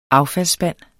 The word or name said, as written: affaldsspand